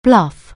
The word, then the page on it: bluff: anglais